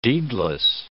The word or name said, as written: deedless